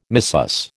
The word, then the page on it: missus: American English